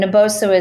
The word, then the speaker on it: Naboso: Yeah, so I've mentioned Naboso several times, and Naboso is N A B O S O, which is how you would spell it.